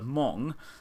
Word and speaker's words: Hmong: With, forgive me if I say this wrong, but I believe it's pronounced with a silent H, the Hmong, the people who, they sort of come from a variety of countries in Southeast Asia.